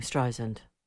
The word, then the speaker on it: Streisand: Streisand. So it's dry sand. sand, Like sand on the beach. How simple can you get?